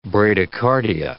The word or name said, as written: bradycardia